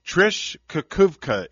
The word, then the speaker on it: Trish Kukuvka: Trish Kukuvka is here, and I hope I said that right, Trish.